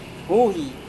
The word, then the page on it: huhi: Serewen